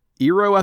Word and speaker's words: Eero: Eero, I think is how it's pronounced. Eero 6 Eero.